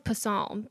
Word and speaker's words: Psalm: I thought it was Psalm, but you told me it's pronounced Psalm.